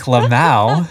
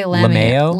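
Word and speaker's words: lmao: Squick, ew, squick, squick, lamau. That'll never happen. – Is that how you pronounce that? Lamau? – Lamau. – I say lameo.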